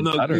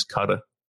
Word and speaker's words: Qatar: You know what? That is how you pronounce it. I know someone who actually, I don't know why or how, but he had some people from there staying with him. – Oh. – And they educated, and they said the pronunciation is Cutter.